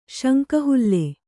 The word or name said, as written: ಶಂಕಹುಲ್ಲೆ